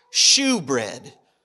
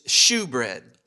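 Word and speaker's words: shewbread: The first is what's called, now listen, it's actually in an Old English, it's pronounced shoe bread. S-H-E-W, not S-H-O-E. It's shoe bread.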